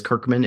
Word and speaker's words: Kirchman: My name, although you pronounced it correctly as Kirchman, it looks like Kirchman, K-I-R-C-H-M-A-N.